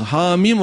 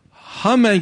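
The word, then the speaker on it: Hamim (حم): Kesik kesik okumak zorundasınız. Hamim olarak okumak zorundasınız. Hame gibi bir şekilde okuyamazsınız. Hume gibi bir şekilde okuyamazsınız.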